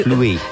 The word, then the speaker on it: pluie: Chante sous la pluie. – Is sing, right? – Chante is sing. – Something sing. Not West Side. Is it West Side Story? No. – No. – Pluie. – Pluie. – Spell that. – P-L-U-I-E.